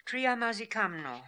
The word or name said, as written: Triamazikamno